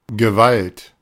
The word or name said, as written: Gewalt